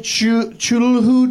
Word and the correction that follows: Cthulhu: dice? – Cthulhu. – On this? Cthulhu? How do you say Cthulhu. – It? – Cthulhu.